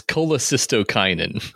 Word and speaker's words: cholecystokinin: In a cholecystokinin. – I actually did look up how to pronounce it. – Oh, is it? – It's cholecystokinin. – Cholecystokinin.